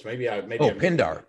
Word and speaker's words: Pindar: I mean, it's Pendergrass, right? Did I say it right? Pendergrass? – What's that? Pendergrass? – Isn't that the guy's name? Pendergrass? In the one of the books? – Pindar. Pindar. – Pindar. Pindar. Yeah.